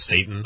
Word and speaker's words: seitan: It's like S-E-I-T-A-N. – Oh, yeah. – At the college, it's literally pronounced Satan.